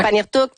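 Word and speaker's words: Pangnirtung: Elle fait ses capsules en direct de Panirtuk. Est-ce que c'est comme ça qu'on prononce? – Oui, oui, oui. – OK, ouais. Panirtuk.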